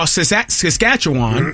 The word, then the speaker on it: Saskatchewan: But what I'm saying is in that person in Moose Jaw, Saskatchewan, did I say it right? – Bless you. – You got it.